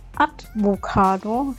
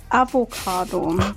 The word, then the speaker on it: Avocado: Ich sage immer Advocado statt Avocado.